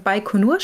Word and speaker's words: Baikonur: Also der Plan war, dass die Rakete in Baikonur startet. Ähm, ob ich das jetzt richtig ausspreche, das weiß ich bis heute noch nicht. Mit ich glaube, Namen das passt schon. So ja, doch.